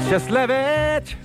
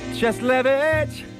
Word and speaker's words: Cieslewicz: When you get to the W, say it like a V. But you can tell everybody you know his name. Just repeat after me, yeah, you'll be proud to exclaim, Cheslevich, Cheslevich, now spell it with me, C-I-E-S-L-E-W-I-C-Z.